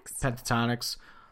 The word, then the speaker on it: Pentatonix: Pentatonix.